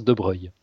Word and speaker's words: de Broglie: Ça s'écrit de Broglie, hein, il y a une subtilité, mais ça se prononce de Broglie.